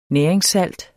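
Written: næringssalt

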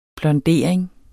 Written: blondering

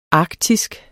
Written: arktisk

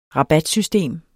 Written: rabatsystem

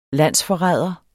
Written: landsforræder

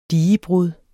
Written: digebrud

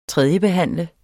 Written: tredjebehandle

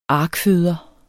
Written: arkføder

